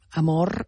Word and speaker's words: amor: Doncs a veure si algú la pot ajudar, perquè diem amor i diem calor i no fem i no pronunciem la R final.